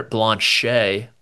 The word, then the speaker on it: Blanchet: Chandler Blanchet, when he's in your group there on Sunday, just know it's pronounced like that.